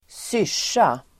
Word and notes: syrsa: [²s'yr_s:a]

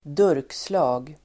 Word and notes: durkslag: ²d'ur:ksla:g

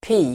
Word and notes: pik: pi:k

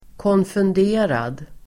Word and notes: konfunderad: [kånfund'e:rad]

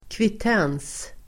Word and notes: kvittens: [kvit'en:s]

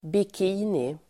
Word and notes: bikini: bik'i:ni

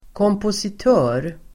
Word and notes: kompositör: [kåmposit'ö:r]

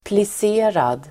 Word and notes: plisserad: [plis'e:rad]

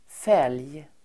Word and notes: fälg: fel:j

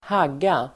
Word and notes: hagga: [²h'ag:a]

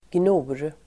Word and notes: gnor: [gno:r]

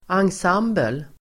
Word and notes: ensemble: angs'am:bel

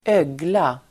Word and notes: ögla: [²'ög:la]

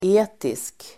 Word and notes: etisk: 'e:tisk

